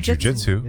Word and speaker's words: jujitsu: How do you say it? Jujitsu? – I say jujitsu. – Yeah. – Jujitsu? – Yeah. – Mm-hmm. Okay. Not jujitsu. Okay.